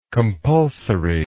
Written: compulsoriness

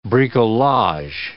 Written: bricolage

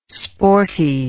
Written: sporty

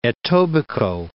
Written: Etobicoke

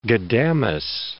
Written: Ghudamis

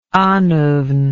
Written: annwfn

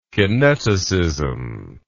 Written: kineticism

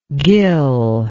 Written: gil

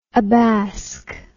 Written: abask

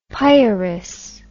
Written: pieris

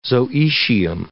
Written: zooecium

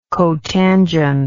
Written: cotangential

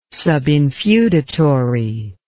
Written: subinfeudatory

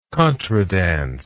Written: contradance

